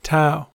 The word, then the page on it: tau: American English